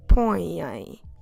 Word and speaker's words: põem: So, como se escreve? Põem. P, O, com til, E, M. Põem.